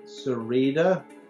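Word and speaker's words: Sereda: My website is my name, David Cerreda, which is spelled S as in Sam, E-R-E-D-A .co.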